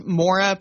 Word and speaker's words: Maura: My handle is at Maura Plays Music, and my first name is spelled M-A-U-R-A. – Like you told me, it's like Laura with an M.